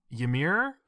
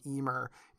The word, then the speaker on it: Ymir: it's like Ymir is how it's pronounced normally, I believe. – Ymir normally, but in the show, it's Ymir is how they pronounce it.